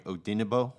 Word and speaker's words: Odenigbo: The G is silent, right? Odenigbo? – Odenigbo. Okay. Odenigbo.